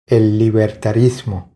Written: El libertarismo

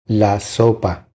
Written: La sopa

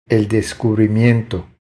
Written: El descubrimiento

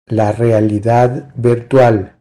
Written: La realidad virtual

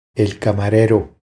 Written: El camarero

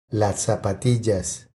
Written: Las zapatillas

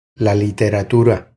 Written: La literatura

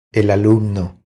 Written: El alumno

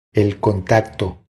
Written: El contacto

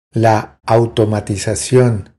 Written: La automatizacion

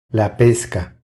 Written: La pesca

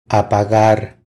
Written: Apagar

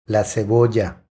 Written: La cebolla

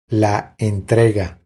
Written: La entrega

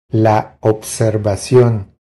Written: La observacion

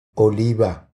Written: Oliva

